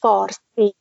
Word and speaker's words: Farsi: We call it Parsi. But because Arab people that can't pronounce P, they call it Farsi.